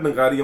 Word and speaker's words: Nagaria: He said Nagaria. I'm like, bro, mainu lagda you're saying it wrong.